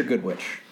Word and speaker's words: Godrich: Wait, is it Godrich or Goodrich? – I was really leaning on you to know the correct pronunciation there. – All right, so it's Godrich. – Let's go with Godrich.